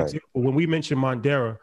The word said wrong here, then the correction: Moderna: Moderna. – When we mentioned Moderna on the podcast, that was in March, right?